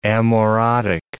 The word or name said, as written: amaurotic